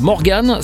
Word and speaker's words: MORGXN: Alors Morgan, ça s'écrit M-O-R-G-X-N, mais ça se prononce Morgan Carr.